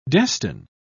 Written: destine

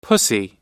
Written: pussy

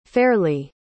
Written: Fairlie

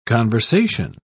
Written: conversation

